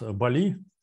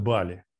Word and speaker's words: Бали: Есть остров такой, вот, его называют у нас Бали. А вот русские называют Бали. Я беседовал с Настей Счастье, она там живет, она говорит, нет, правильно говорить Бали. Но, надо сказать, русские почему-то считают, что надо говорить Бали, делая акцент на первом слоге.